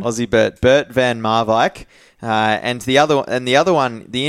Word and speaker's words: Bert van Marwijk: Firstly, he's Dutch from the Netherlands, Bert van Marwijk. How did I go? – Bert van Marwijk.